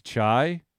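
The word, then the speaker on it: Tchia: It's T-C-H-I-A, so it's Chai.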